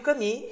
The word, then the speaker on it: Camus: Se escreve Camus, né? Mas a pronúncia é Camus.